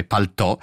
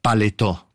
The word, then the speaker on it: paltò: L'origine la si trova nel francese paltò, in italiano con l'accento grave paltò, sì. E qualche volta in forma ancora più popolare, oggi un po' desueta, paletò, come appunto dice Guccini.